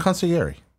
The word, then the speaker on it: consigliere: Consigliere.